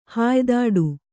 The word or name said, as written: ಹಾಯ್ದಾಡು